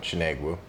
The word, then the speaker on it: Chinegwu: Oh, I usually don't even use it, but Chinegwu. – Chinegwu. – Yeah. – Because I was going with a more Asian pronunciation.